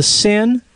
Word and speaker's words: Seine: It's called the Seine, but it's not spelled like S-I-N. It's called S-E-I-N-E.